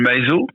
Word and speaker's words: Maeso: Eh ben, écoute, moi, on peut, moi je m'appelle Maiso, ça s'écrit M-A-E-S-O. Après, j'ai un Facebook, qui s'appelle Maizo donc, et aussi euh, Jean-Paul Maizo.